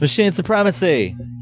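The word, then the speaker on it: Machinae Supremacy: How do you pronounce it? – It's Machine Supremacy. – Machina Supremacy. – Elex made fun of me for saying Machine Supremacy, so I sent him a video of the band saying it themselves.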